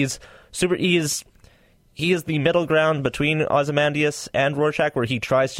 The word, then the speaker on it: Ozymandias: Ozymandias. – Ozymandias.